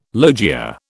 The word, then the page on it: logia: British English